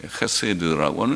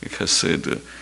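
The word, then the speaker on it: 헤세드 (חסד): 거기는 인애 같은 말, 헤세드라고 하는 히브리 말로는 헤세드, 오늘 케세, 발음이 해서 케세드라고도 우리가 쓸 수 있고 헤세드라고도 하는데 해보다는 해에 가깝습니다. 헤세드.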